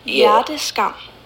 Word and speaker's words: Hjerteskam: Hjerteskam? – Ja, det udtales med sådan et rullende R og et spidst T.